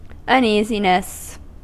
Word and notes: Sorge: /ˈzɔʁɡə/

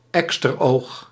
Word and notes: cor: France; [lə kɔʁ]

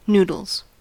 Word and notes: noodles: American English

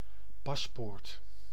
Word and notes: paspoort: /ˈpɑsˌpoːrt/; [ˈpɑsˌpʊːrt]

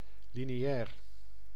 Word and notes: primaire: [pʁi.mɛʁ]; [pʁi.mɛːʁ]; [pʁi.maɛ̯ʁ]

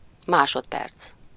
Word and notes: instant: /ɛ̃s.tɑ̃/; France

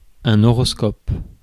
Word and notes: horoscope: /ɔ.ʁɔs.kɔp/; France

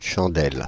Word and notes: chandelle: France; [ʃɑ̃.dɛl]; Paris

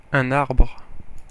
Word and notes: arbre: /aʁbʁ/

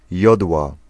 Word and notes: sapin: France; [sa.pɛ̃]

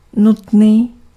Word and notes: nécessaire: [ne.se.sɛʁ]; /ne.sɛ.sɛʁ/

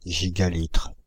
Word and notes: gigalitre: /ʒi.ɡa.litʁ/; France (Île-de-France)

